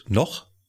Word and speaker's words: Noch: Kennt ihr, ich weiß gar nicht, wie es ausgesprochen wird, noch? N-O-C-H? – Ja. – Ja. – ja. Noch.